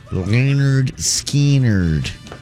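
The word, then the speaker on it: Lynyrd Skynyrd: 1973, Leonard Skinner released a debut album pronounced Leonard Skinnerd.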